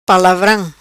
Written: palavram

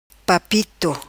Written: Papito

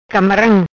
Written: Camaram

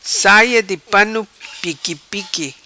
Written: Saia Di Pano Piqui Piqui

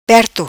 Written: Perto

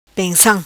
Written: Pensam

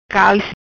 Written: Calis

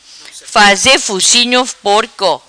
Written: faze fucinho porco

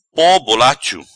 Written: Po bolacho

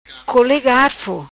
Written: Cole Garfo

